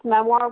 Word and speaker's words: memoir: She pronounced memoir with a soft R, memoir, and talked about hers constantly.